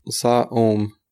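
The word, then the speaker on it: sinthome: There's a French philosopher by the name of Jacques Lacan who writes the word symptom as sa-om. He writes the word symptom in a way that would be pronounced sa-om, and that's the same way you would pronounce holy man, saint man.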